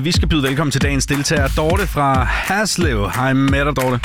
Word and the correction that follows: Haslev: Udtalte jeg det rigtigt nok, Haslev? – Nej, Haslev.